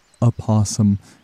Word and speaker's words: opossum: But according to our modern dictionaries, they seem to be unanimous in describing the pronunciation of this word as opossum in order to distinguish it from a creature known as possum.